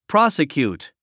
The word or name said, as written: prosecute